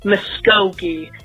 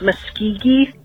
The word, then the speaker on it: Muskogee: The local area you were calling happened to be in the state of Oklahoma, and it's pronounced Muskogee. M-U-S-K-O-G-E-E, not Muskegee.